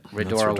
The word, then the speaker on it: Rhodora: Rhodora, Rhodora.